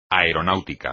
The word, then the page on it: aeronáutica: espagnol